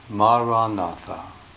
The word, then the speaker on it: Maranatha: If you choose this word, say it as four syllables. Ma-ra-na-tha. Ma-ra-na-tha.